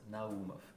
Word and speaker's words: Naumov: So right uh, pronunciation is Naumov with emphasis Naum, on the Naum, second uh, Naumov.